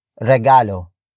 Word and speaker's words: regalo: And that is regalo. Regalo. Regalo. Regalo is spelled as R-E-G-A-L-O.